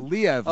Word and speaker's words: Liev: Liev.